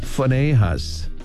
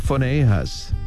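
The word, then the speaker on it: Phinehas: 자, 그러면 자 비느하스부터 한번 따라해보세요. 이렇게 발음합니다. 퍼네이하스 PH로 발음을 해요. 퍼네이하스 이렇게 발음하시고 그 다음 엘르아살은 엘리에이저 한번 더요.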